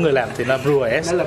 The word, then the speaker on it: Brewers: Hop Coffee Brewers Brewers của em có S không nhỉ? – Dạ có. – Thế thì anh đọc đúng anh anh phát âm đúng.